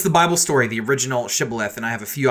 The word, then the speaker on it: shibboleth: They did not say shibboleth, they pronounce the word Sibboleth.